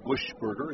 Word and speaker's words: Buchberger: His trip on his name, he spells it Buchberger, but Buschberger is how it's pronounced.